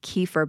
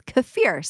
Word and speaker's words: kefir: I actually think I have always pronounced it as kefir, but I think I was corrected that it's kefir.